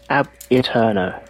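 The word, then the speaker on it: Ab Aeterno: Ab Eterno. – Well, that sounds so easy. – Ab Eterno, yep. – Would you go with that? – The first syllable is pronounced like Fonzie's A. Ab Eterno.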